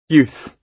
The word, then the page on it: youth: British English